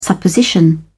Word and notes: supposition: British English

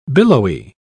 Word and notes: billowy: British English

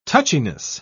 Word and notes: touchiness: British English

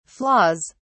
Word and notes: flaws: British English

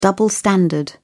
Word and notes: double-standard: British English

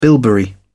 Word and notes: bilberry: British English

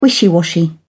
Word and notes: wishy washy: British English

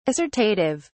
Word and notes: assortative: British English

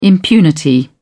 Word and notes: impunity: British English

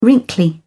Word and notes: wrinkly: British English

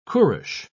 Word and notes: qurush: British English

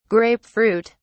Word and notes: grape fruit: British English

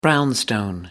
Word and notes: brownstone: American English

